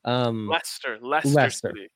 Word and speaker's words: Leicester: Leicester City.